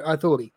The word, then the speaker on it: آثوري: تلفظ اشوريه ولا اثوريه؟ – يعني أصدقائي الأشوريين يقولون آثوري